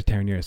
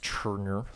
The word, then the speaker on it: Terenure: Um, so if you're you did go to Terenure, so I mean, it's in the water. – It's actually pronounced Turner, so get your facts right.